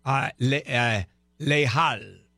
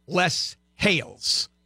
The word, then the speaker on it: Les Halles: And his the highlight of his culinary career was the executive chef at Brasserie Les Halles, or the way we would pronounce it, Les Hales.